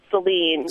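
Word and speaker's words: Saline: With us from Salem in Michigan. – Yeah, it's actually pronounced Celine.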